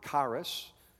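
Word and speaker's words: charis (χάρις): Grace is from a Greek word, charis. It starts with an X, but, you know, sometimes like it sounds like a K, like Christ, C-H. And charis is something like C-H-A-R-I-S.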